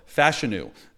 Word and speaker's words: Fashanu: Fashanu is his last name. I know that's how you say it.